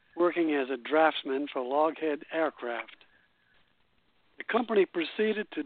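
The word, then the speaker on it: Loughead: This is pronounced Loghead.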